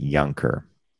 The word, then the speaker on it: younker: And the last word I'm going to say, it's younker. I'm going to pronounce it younker. So it's like younger would be younger. There's a K there, so younker.